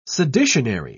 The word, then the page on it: seditionary: British English